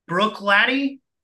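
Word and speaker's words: Bruichladdich: So I am, and if we have anybody listening from Scotland, I apologize for the pronunciation of this. Brook Laddie is how I've been told to pronounce it.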